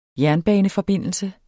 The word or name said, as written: jernbaneforbindelse